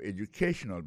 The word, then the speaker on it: educational: We'll be talking about your educational background. Alors, accent tonique là, quand on peut se publier là, il tombe sous troisième syllabe là.